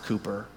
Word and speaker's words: Cowper: And the man who wrote it in the 18th century was also an Anglican, I think Anglican minister maybe, William Cowper, spelled Cowper, but pronounced Cooper.